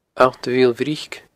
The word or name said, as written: Allt a Mhill Bhric